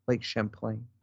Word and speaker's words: Champlain: Champlain, excuse me. Let me get the pronunciation correct. Lake Champlain.